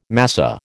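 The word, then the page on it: massé: American English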